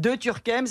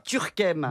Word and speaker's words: Turckheim: Moi, ça fait des années que vous me dites que je m'appelle De Turckheim. Ça fait des années que je vous dis qu'on prononce pas la particule.